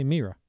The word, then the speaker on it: Mira: Now, I always thought it was Myra Sorvino. – I thought it was Mira. – Well, Mira. Well, I looked it up for how to pronounce. – Mm-hmm. – They say Mira Sorvino.